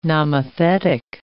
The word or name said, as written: nomothetic